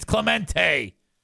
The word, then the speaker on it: Clemente: you're saying his name wrong. It's not Clemente, it's Clemente.